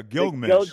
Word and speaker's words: Gilgamesh: And in the Galapchi, no, let me say it properly. – Yeah, Gilgamesh.